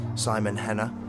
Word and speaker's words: Simon Henner: It's from this year and released on Alter K by a French guy called, I'll pronounce it in English, like a Terry Henry sort of way, Simon Henner.